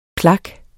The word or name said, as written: plak